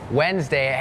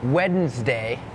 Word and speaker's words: Wednesday: ขอให้ใจเพื่อว่าเริ่มเลยก่อนเริ่มรายการพูดถึงคำว่าอะไรน้องๆจำได้ไหมคะ silent letters ใช่ไหมคะนั่นก็คือตัวอักษรที่ไม่ออกเสียงแต่อย่างคำนี้ค่ะเป็นคำหนึ่งที่มี silent letters Wednesday has that D W E D it's not wed Wednesday Wednesday D is silent